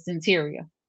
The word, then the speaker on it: Cyntiria: The pronunciation of the name is Cyntiria.